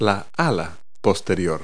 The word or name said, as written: hindwing